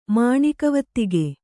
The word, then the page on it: ಮಾಣಿಕವತ್ತಿಗೆ: Kannada